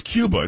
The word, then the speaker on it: Cuba: Why are you saying Cuba? – Huh? – It's Cuba. It's not Cuba Gooding Jr. It's definitely Cuba. Okay. He pronounces like the country.